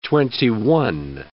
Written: twenty-one

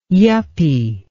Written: yuppy

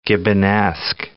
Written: Gibbonesque

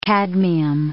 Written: cadmium

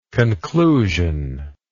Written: conclusionally

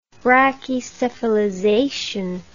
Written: brachycephalization

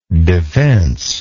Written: defencelessness

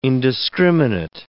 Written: indiscriminate

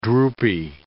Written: droopy

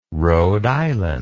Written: Rhode Island

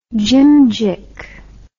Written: jimjick

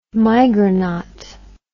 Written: migronaut

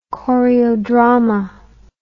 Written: choreodrama

